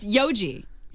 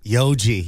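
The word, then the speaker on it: Yojie: What's it's Yoji. – Yoji, yes.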